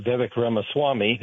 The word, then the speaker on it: Vivek Ramaswamy: I think if you look at the debate, and especially of Vivek Ramaswamy, who I go to India a lot, so I can pronounce his name.